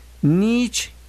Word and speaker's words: Nietzsche: Nietzsche, casi como estornudo, decía una maestra en la prepa. Nietzsche.